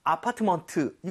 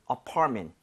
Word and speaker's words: apartment: 이것도 아파트먼트 이건 아니죠. – 예. – Right? So 강세를 제대로 주면 ment가 그렇게 발음이 돼요. 여기 강세 주고 한번 해보면 네? – apartment보다는 apartment. apartment.